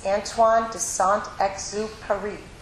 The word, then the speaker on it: Antoine de Saint-Exupéry: The author's name is Antoine de Saint-Exupéry.